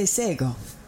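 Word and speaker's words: Shé:kon: When I first opened the show, you heard me say Sego. That is a Gonyageha word for hello, but it's not spelled like it sounds. It's spelled SHE:KON. The letter K sounds like a G.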